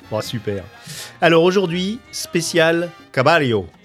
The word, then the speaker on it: Caballo: Et notamment pour une première chanson qui se nomme Caballo Viejo, je l'ai bien dit. – Oui, alors les deux L, c'est prononcé comme un I chez nous.